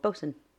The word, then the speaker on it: boatswain: And also, another interesting fact, it is spelled boatswain. – Boatswain. – Yes, and it's called bosun.